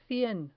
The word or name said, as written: cien